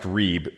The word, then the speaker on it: rib: Again, the Hebrew word here that's translated quarrel is a transliteration that looks like the word rib, R-I-B, like, "I'm going to rib you." And it's pronounced reeb.